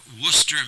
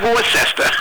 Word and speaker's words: Worcester: Well, my mother was from Worcester, Massachusetts. – Yeah, not Worcester.